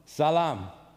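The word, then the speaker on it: Salam (سلام): Salam, which means peace.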